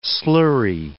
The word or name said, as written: slurry